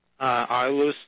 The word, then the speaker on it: Ilus: Ilus Davis Park. It's I-L-U-S, Davis Park.